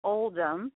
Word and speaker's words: Oldham: The name is Oldham. It's spelled Old Ham.